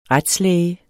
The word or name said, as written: retslæge